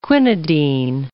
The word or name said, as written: quinidine